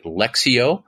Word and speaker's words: Lectio: And I've heard people pronounce it Lectio, but it's L-E-C-T-I-O, like lectionary, and then Divina, which is God.